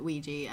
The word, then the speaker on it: Ouija: Just going back to a question that you asked me between the difference between Ouija and... Did I say that right? – Yeah. – Ouija. – Ouija. Ouija, Ouija. As long as it's not Luigi.